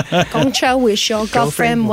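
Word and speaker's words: Gong cha: Can you imagine if I've been saying that wrong this whole episode? – Yeah. – No, I think it's right. – Gongcha. – No, it's right. Gongcha.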